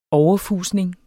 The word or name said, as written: overfusning